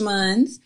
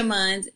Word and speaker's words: Munns: I'm on Instagram, Asia Munns. Facebook and Twitter, but I don't tweet, and I'm on Clubhouse also. I love Clubhouse. Okay, Asia Munns, and first name is spelled A-J-I-A, last name M-U-N-N-S.